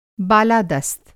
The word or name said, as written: बालादस्त